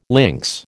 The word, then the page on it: links: American English